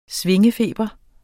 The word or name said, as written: svingefeber